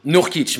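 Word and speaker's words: Nurkić: Nurkic. J'ai pas envie d'écorcher son nom. ou aussi... Nurkic, merci, Nurkic J'allais dire un truc un peu bizarre.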